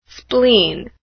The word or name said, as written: spleen